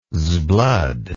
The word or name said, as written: sblood